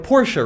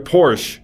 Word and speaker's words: Porsche: Porsche is you're completely forgetting that E is at the end of that word.